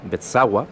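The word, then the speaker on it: Beczała: Por su parte, el tenor polaco Piotr Bezała, que la pronunciación de esa L en polaco es más o menos así, Bezała,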